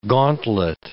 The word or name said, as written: gauntlet